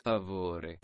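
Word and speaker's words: per favore: Okay, well, notwithstanding your dangerous and frightening left turns there, Joe, my friend says the correct pronunciation is. Favore.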